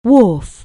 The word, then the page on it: wharf: anglais